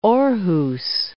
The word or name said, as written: Aarhus